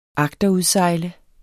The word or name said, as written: AGTERUDSEJLEDE